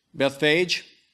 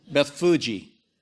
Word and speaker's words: Bethphage: And most of you would pronounce it how? Bethphage? I thought so too. And then I looked up in my Bible pronunciation. It's Beth-Fu-Gi. Beth-Fu-Gi. It's like Beth, B-E-T-H, F-U-H, dash, J-E-E.